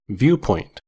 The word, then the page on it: viewpoint: American English